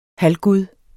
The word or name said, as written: halvgud